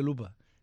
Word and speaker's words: lupa: No vaya a ser que, como Carmen dijo un día, Lipa... Y le dijeron, no se dice Lipa, se dice Lupa.